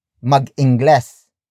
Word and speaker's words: mag-ingles: For example, mag-ingles. Mag-ingles. Mag-ingles. Mag-ingles is spelled as M-A-G hyphen capital I-N-G-G-L-E-S.